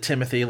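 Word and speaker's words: Timothée: she pronounces it Timothy like a regular human being, which is like good and fine.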